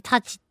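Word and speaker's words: 太刀: えー、刀、太い刀の魚、あの太い刀と書いてタチね、えー、と読みますが、そのタチウオというふうに書きます。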